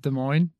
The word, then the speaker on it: Des Moines: Is it Des Moines? – Yep. On to the Des Moines Challenge.